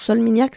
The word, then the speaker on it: Solminihac: Alors, Solmignac, ça s'écrit S-O-L-M-I-N-I-H-A-C. Voilà.